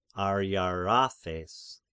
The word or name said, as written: Ariarathes